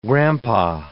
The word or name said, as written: grandpa